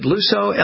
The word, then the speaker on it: Luso: Neil, what about, is it Luso, L-U-S-O? Am I saying that right? – Yes.